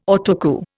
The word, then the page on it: Autocue: Indian